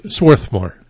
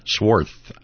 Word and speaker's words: Swarthmore: I'm saying it right, aren't I? – Swarthmore. – Swarth. – Yeah. – Got a TH in there. Swarthmore.